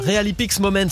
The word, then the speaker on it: Real Epics Moments: Real Epics Moments. Ouais, en fait, il faut le prononcer comme ça. Real Epics à la française et Moments à l'anglaise.